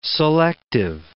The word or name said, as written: selective